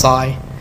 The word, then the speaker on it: Tsi: It says, Sabrina to Cy, T-S-I, pronounced Cy.